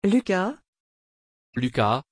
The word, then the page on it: luca: French